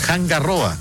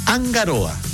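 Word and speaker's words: Hanga Roa: Lo mismo que algunos dicen Hangaroa, es Angaroa. La R no se pronuncia como una R, sino que como una R.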